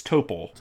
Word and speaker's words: Topol: Is it pronounced Topol? – I think it's Topol.